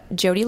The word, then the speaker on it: Jodi: It's Jody Labossiere, J-O-D-I, and then L-A, B like boy, O-S-S like Sam, I-E-R-E.